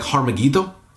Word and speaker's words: Armageddon: And it says in verse 16 that they gathered the kings together in the place that in Hebrew is called Armageddon. And in more accurate pronunciation would be like Harmageddon and it's a plain.